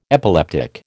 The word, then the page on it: epileptic: American English